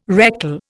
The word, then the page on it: rectal: Indian